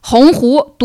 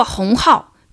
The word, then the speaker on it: 鸿鹄: 北大校长还曾把“ 鸿鹄” 读作“ 鸿浩”，清华校长还不认识“ 瓢” 呢。